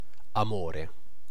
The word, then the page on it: amore: /a.ˈmo.re/